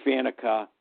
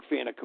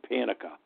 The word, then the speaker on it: Faneca: I called him Panica, like pancake, and everything like that.